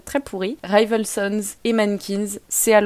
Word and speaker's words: MNNQNS: vous pouvez écouter le nouvel album de Mankins. Donc je répète, ça s'écrit M-N-N-Q-N-S.